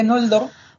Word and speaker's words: Noldor: sino una nasal velar, o sea, una especie de ng, con lo que Noldor, sí, con lo que Noldor sería más bien Moldor. – Noldor, vale, sí. Como si fuera, es horrible pronunciar como si fuera, esto. – Un poco, como si fuera Ngoldor, pero todo junto, Noldor.